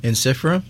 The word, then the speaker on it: Ensiferum: All right, so let um, let's move back on to interferum. – Yeah. Did I say it correctly? Yes. Yeah. – Maybe. – Yeah. How do you say it again? – Ensiferum.